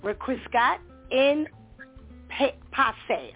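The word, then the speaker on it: requiescat in pace: Rest in peace has been around for a long, long, long time, and it comes from a Latin phrase, requiescat in passe.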